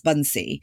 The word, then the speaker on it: Bansi: So my actual name is Bunsi and it's spelled B-A-N-S-I.